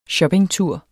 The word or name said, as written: shoppingtur